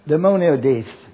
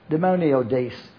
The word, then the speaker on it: daimoniodes: D-A-I-M-O-N-I-Z-O-M-A-I. Daimonadizomai. And then the word that's in our verse is in James 3:15. D-A-I-M-O-N-I-O-D-E-S. Demoniodes. Demoniodes. demoniodes.